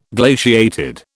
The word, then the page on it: glaciated: British English